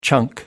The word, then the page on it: chunk: American English